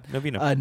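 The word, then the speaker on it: Novinha: How would you pronounce that? – I think it's Novena. – Like that? – Yeah, Novena.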